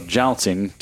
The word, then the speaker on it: jouncing: So jouncing to rhyme with bouncing is literally like bouncing the, the front or rear of the car.